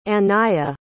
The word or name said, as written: Anayah